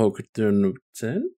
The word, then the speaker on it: Hokuto no Ken: But I'm glad because I want to try out Hokuto no Ken. Did I say it right? Yeah, Hokuto no Ken. – That's literally how they say it.